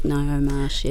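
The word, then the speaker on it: Ngaio Marsh: Is it Naomarsh? – Oh, yeah, I think Naomarsh, yeah. Naomarsh, yeah.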